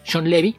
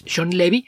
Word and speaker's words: Shawn Levy: Entonces, por ejemplo, un caso reciente, el director de The Adam Project, Free Guy y próximamente Deadpool 3, Sean Levy, se pronuncia así, tal y como suena. Este apellido es... existe también en América Latina, es Sean Levy,